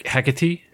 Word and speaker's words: Hecate: it's the blood is the sacrifice to the goddess Hecate, I think is how it's pronounced.